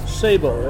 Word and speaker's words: Sabo: Sabo, S-A-B-O.